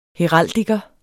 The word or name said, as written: heraldiker